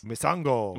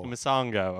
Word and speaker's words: Misango: It included a new fighter, which, how do you say this? – Misango. – Misango, okay.